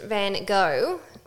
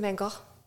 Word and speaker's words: van Gogh: Yeah, so it's not Vincent van Gogh. It's Vincent van Gogh. – Yes, because it's Dutch.